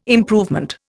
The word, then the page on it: improvement: Indian